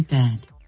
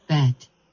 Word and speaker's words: bed: Se pronuncia con una de final, no con una T final. Con una T final sería bet.